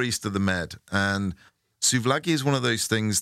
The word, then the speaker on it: souvlaki: I think I'm saying souvlaki. – It is souv, S-O-U-V-L-A-K-I. Souvlaki.